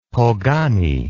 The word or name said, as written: pogany